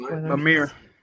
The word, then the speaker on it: Mimir: Yeah, Mimir. What? How do you say his name? – Mimir.